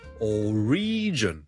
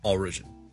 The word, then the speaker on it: origin: Number two 咧，如果讀成 origin 咧就會怪怪地啦，因為個重音其實 origin o r i g i n 個來源咧，我哋個重音係第一個音節嘅。所以 single law origin origin。